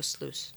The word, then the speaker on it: sluice: They have the gemstone sluice, which, as Linda, the cavewoman, will tell you, is spelled like juice. – Okay. – U-I-C-E. So sluice, U-I-C-E.